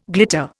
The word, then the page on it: glitter: Indian